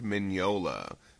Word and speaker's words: Mignola: Mike Mignola, phonetically spelled M-I-N-Y-O-L-A. Thanks Reddit user Middleway, Craig McKnight, and Mike Corley for giving us this info.